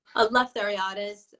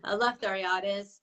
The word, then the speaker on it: Eleftheriadis: Eleftheriadis. I mean, the more Greek you are, the more of a good pronunciation you get. So yeah, Eleftheriadis.